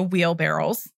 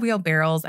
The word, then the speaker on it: wheelbarrows: Although I said wheelbarrows, I meant wheelbarrows. – Okay. – Because I always say that wrong. And I just learned that it was barrow.